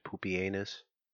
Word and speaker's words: Pupienus: There is a guy named Pupienus.